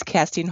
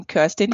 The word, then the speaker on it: Kerstin: I'm from Germany and my name is Kerstin Heuer, but since I'm in Canada, people pronounce my name Kirsten Heuer. When I introduce myself, I never know if I should say Kirsten Heuer or Kerstin Heuer.